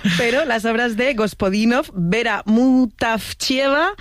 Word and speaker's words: Vera Mutavcheva: Zdravka Eftimova y Vera Mutavcheva.